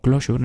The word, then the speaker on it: closure: Esto se hace mediante un concepto que se llama, se escribe en inglés closure, aunque se dice como algo así como closure en inglés,